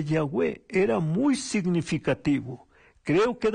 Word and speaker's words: Yahweh: En hebreo se describe con Y, H, W y H sin vocales. Cuando se agregan las vocales se pronuncia Yahweh, que en español sería Jehová.